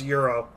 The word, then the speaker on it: gyro: No, a gyro. – It's pronounced euro, really, but... I won't get politically correct with that, but gyro, euro.